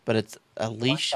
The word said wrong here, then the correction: Leica: Leica. What is it, sorry? – Leica. – Leica. – Leica. – Leica. And, yeah. – Like a glove?